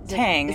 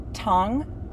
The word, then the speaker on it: Tang: Tang. – Is it just Tang? – Yeah, that is the commonly mispronounced version that we just run with. – Oh, is there a real version? – Tong.